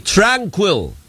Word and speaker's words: Tranquil: Kasama na po natin sa Zoom si Attorney Tranquil. Dapat ganun ang bigkas sa Salvador III,